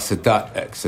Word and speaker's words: Sadat X: Sadat X. You ever heard of Anwar Sadat? – Yeah, I know him, yeah. – Right, so Sadat X.